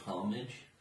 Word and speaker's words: homage: Did you just say homage? – Yeah. That's what he says in the fucking movie! – Pretty sure the H is silent. – Homage? It's homage.